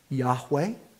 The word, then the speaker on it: Yahweh: And it's an unpronounceable name, but it's most often phonetically pronounced Yahweh or Jehovah.